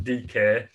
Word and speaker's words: Dike: Now, you spell his last name D-I-K-E. So obviously that led to much hilarity, shall we say. But no, apparently it is pronounced DK.